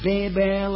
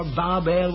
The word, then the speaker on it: Babel: Remember, at first it was called Babel or Babel, whatever way you like to pronounce it. B-A-B-E-L.